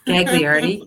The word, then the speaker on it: Gagliardi: Gagliardi.